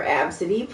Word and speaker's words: Abcde: Tracy Redford told ABC affiliate KABC that several weeks ago she and daughter Absidy, pronounced Absidy, is spelled A-B-C-D-E, were boarding a plane at John Wayne Airport in Orange County, California on their way home to El Paso.